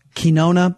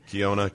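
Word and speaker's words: Quijano: Now, there was also a little bit, I did notice in the refereeing, she was much more stern, the moderator, Elaine, I'm mispronouncing her last name, Kinona, I believe. – Kiona.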